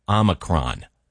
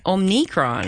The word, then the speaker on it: Omicron: And in classical Greek, it was pronounced with the second syllable sounding like me. – Well, that's interesting. Now, I know linguistically. – Omicron.